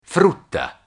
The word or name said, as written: frutta